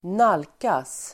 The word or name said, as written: nalkas